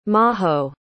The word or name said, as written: maho